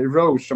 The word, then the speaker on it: Roche: Alltså, många hade kanske glömt bort hur bra Jamie Roche var, eller Roche om man ska uttala det lite bättre kanske.